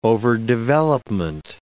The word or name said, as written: overdevelopment